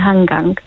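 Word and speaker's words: Han Kang: Tylko że jak wymawiamy te dwa człony razem, to troszeczkę się zmienia wymowa i wtedy mówimy Hangang. A Gang to jest rzeka. – Ale czytamy Gang, nie Kang. – Jeżeli te słowa wymawiamy łącznie, to po półgłosce M jest wymowa G.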